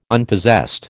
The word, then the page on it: Unpossessed: American English